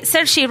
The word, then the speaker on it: Saoirse: Eh, Sergi Ronan, que se escribe Saoirse,